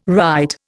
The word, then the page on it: rite: Indian